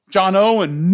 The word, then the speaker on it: John Owen: John Owen knew what was really important. Did I say that right? Yeah, John Owen knew what was really important.